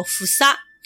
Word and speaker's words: 房: 屋檐的四个角啊，从水影幕中间会垂挂四个不同颜色的神节，这个叫做房，房子的房，念作福沙，它是象征原本的四根柱子。